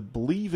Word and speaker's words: Bleav: That's B L E A V in Angels podcast. I have with me the host of the Bleav in Angels podcast.